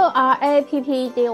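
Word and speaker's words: Wrapped: 是 W R A P P E D，我要先跟大家澄清一下这件事情。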